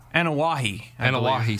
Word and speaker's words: Anoa'i: Yes, Matt Inouye. – Anoa'i, sorry. Yes, those Samoan names are difficult to pronounce.